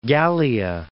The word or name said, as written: Gallia